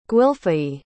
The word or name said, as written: gwylfai